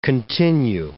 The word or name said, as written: continue